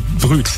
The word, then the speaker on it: Brut: Das heißt nicht Brut, ne, sondern Brüt.